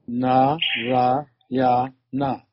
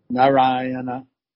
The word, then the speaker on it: Narayana: And it has four syllables. – 而这个名字的话含有四个音节。 – Na, ra, ya, na. – 就是这四个音节。 – Narayana. – Narayana. – Yes, sir. And so according to the Vishnu Dutas, just by saying the name Narayana once in a helpless condition, Without expecting anything, Ajamil was chanting the holy name in Nam Bas.